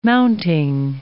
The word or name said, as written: mounting